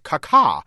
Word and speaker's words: Kaká: The great Brazilian's name is pronounced Kaká.